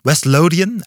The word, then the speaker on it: West Lothian: West Lothian, I don't know if I pronounce it correctly.